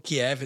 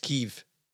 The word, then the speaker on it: Kyiv: It's not Kiev, it's Kyiv. Just remember that. Proper pronunciation, everything.